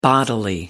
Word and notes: bodily: American English

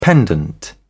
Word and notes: pendant: British English

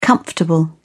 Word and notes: comfortable: British English

